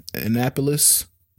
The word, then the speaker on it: Annapolis: Uh, he's in Black and Blue, of course, Waist Deep, Four Brothers, Transformers, uh, Annapolis.